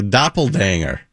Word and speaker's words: doppelganger: A doppelganger. – How is this how you say that word when you see you have a person that looks exactly like you? – Mm-hmm. – That's it. You got it.